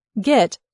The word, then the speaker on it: Git: As SCM tools go, Git, pronounced with a hard G like goof but rhymes with pit, isn't the easiest software to learn, but it's not very difficult either.